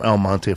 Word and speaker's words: El Monte: He's from El Monte Flores. – Yeah, so he's from El. Say it again. – El Monte. – From El Monte, bro.